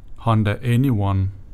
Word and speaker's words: Honda e:Ny1: Prøv at sige det på engelsk, så giver det mening. Honda Anyone.